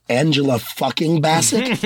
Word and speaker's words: Angela Bassett: I would like to point out that y'all pronounced her name wrong. It's Angela fucking Bassett.